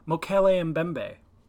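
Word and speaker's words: Mokele-mbembe: Mokele Mokele Mbembe. – No, what are you doing? – This is how they pronounce it on TV. Every documentary I've seen, it's Mokele Mbembe.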